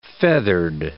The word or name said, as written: feathered